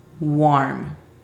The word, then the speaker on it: warm: La pronunciación fonética es U-A-R-M. Warm.